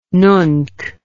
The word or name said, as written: nunc